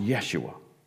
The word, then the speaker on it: Yeshua: That's a transliteration of the Hebrew word that comes from the word Joshua, that's pronounced Yeshua.